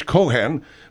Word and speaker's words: Cohan: Cohan, and that's kind of a weird way of saying that name, ain't it? Isn't it usually Cohen? – There's two ways of pronouncing it. There's the Irish Cohan.